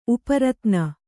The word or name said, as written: ಉಪರಿತನ